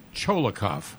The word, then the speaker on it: Tcholakov: He's Tcholakov. – Bulgarian. There you go. You know it better than I do. – Say again. – Tcholakov.